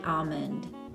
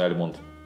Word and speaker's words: almond: Ну, а тем временем наш лосось и миндаль, они все пишутся с буквой Л, но, они, но она не произносится. То есть мы говорим salmon и almond. – Да, не сальмон и альмонд.